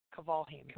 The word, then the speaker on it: Kvalheim: Kvalheim. – Correct. – Am I saying it right? Kvalheim. Okay. – Yes. – Cool. Kvalheim.